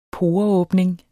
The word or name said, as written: poreåbning